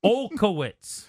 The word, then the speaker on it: Olkowitz: You're not saying it right. – Neil Okowitz. – Okowitz. – Oh, my God. Neil, if we can't even get him to say it right, how's he going to spell it? Olkowitz. – Olkowitz. – There it is. – N-E-I-L.